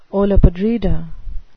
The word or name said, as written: olla podrida